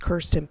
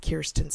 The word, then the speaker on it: Kirsten: My name is spelled to be pronounced Kirsten, but I pronounce it Kirsten.